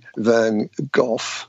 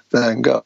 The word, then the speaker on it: Van Gogh: And it depends if you're UK, you would say Van Gogh, but if you're in the States, and I imagine Canada, you would say Van Gogh.